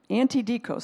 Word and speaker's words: antidikos: The word is antitikos. Antidikos, sorry, I said it wrong.